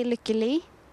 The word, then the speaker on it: Lykke Li: I say Lykke Lee. – Lykke Lee? – Lykke Lee. – Lykke Lee? – Lykke Lee. – Lykke Lee. – Yeah, almost, yeah.